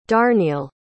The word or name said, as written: darneil